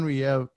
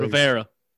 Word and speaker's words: Rivera: I think Haskins is going to have a good few games, and Ron Rivera, is Ron Riev can't even say his name properly. – Rivera.